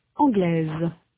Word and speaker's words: anglaise: In sound, the feminine form anglaise has a final consonant z that is not heard in the masculine form anglais. In the masculine, the final s is not pronounced. In the feminine, s represents the sound z.